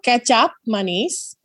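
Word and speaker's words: kecap manis: It's spelled K-E-C-A-P. That's the first name. That sounds exactly like ketchup. And then manis is how that is spelled how it sounds, which is M-A-N-I-S.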